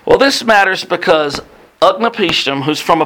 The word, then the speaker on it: Utnapishtim: Utnapishtim. Utnapishtim.